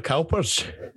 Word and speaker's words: Cowper: The Cowpers.